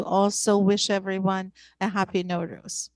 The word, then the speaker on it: Nowruz: Well, no, in our language, Persian means new. So basically, when we say no ruz, ruz means day.